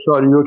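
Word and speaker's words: chariot: یک تلفظش دارم درست میگم شاریو هست درسته؟ این کلمه رو من درست دارم تلفظ میکنم آقای منصوری؟ – بله بله شاریو کلمه فرانسه.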